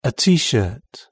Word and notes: a tshirt: British English

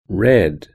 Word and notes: red: British English

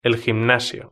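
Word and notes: el gimnasio: Spanish (Spain)

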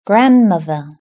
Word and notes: grandmother: British English

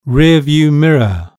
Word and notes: rear view mirror: British English

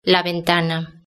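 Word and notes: la ventana: Spanish (Spain)

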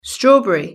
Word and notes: strawberry: British English

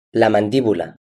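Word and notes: la mandibula: Spanish (Spain)

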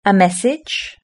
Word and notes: a message: British English

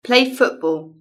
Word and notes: play football: British English

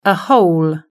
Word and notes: a hole: British English

